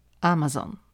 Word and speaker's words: Amazon: Ja Wam opowiem, jak to działa tutaj, ponieważ Amazon Amazon, jak w Ameryce się akcentuje, zrewolucjonizował zakupy w Stanach Zjednoczonych i na całym świecie.